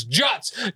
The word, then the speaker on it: Jutz: And then, like, people are being seated, and, like, he spelt my name wrong, and I was like, it's Jutz, J-U-T-Z, and he spelled it J-U-T-S, and I was like, that's not my name, you need to fix it!